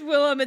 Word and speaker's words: Willem: I think I'm still attracted to older William Dafoe as well. – Willem. – Is, fuck. Oh yeah, it is Willem. It's spelled W-I-L-L-E-M.